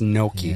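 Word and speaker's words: gnocchi: Starts with a G, but it's pronounced Noki. – Yeah. – It's a silent G.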